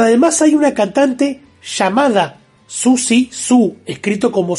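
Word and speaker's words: Siouxsie Sioux: Que yo de hecho me enteré que además de ella, que es Susie Sue, como se pronuncia, toda la vida dije Siuxi.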